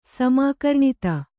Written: ಸಮಾಕರ್ಣಿತ

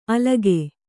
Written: ಅಲಗೆ

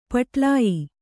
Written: ಪಟ್ಲಾಯಿ

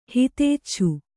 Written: ಹಿತೇಚ್ಛು

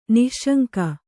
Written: ನಿಃಶಂಕ

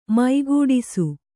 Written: ಮೈಗೂಡಿಸು

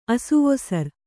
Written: ಅಸುವೊಸರ್

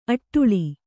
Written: ಅಡಳು

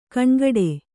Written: ಘನಘಟ್ಟಿ

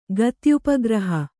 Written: ಗತ್ಯುಪಗ್ರಹ

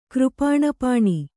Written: ಕೃಪಾಣಪಾಣಿ

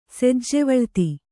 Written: ಸೆಜ್ಜೆವಳ್ತಿ

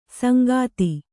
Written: ಸಂಘಾತಿ

